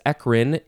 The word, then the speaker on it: eccrine: Eccrine is E-C-C-R-I-N-E. You can also pronounce it eccrine or eccrine. Eccrine, eccrine, eccrine.